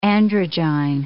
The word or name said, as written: androgyne